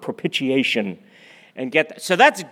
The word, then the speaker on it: propitiation: And he always used to say, it's not propitiation, it's propitiation.